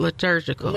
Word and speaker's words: liturgical: Is it liturgical? – Liturgical. – Oh, I said it right.